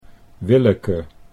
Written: Willeke

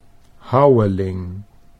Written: Houweling

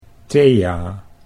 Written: Thea

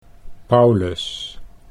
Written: Paulus

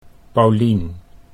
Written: Paulien

